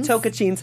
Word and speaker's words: Tocantins: Tocantins.